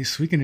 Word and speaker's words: Suikoden: Yes, Suikoden.